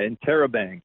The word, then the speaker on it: Interrobang: So the new album, how do you pronounce it? Interrobang, is that how you say it? – Yeah, Interrobang.